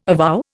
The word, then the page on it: avow: Indian